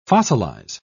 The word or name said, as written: fossilize